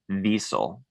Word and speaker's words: Wiesel: I believe you pronounce his name Wiesel, but Elie Wiesel.